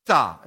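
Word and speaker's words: Pesach: It's the word Pesah. It's a long A, and the word there that you would read if you were reading your copy of the Hebrew Bible would be Pesah.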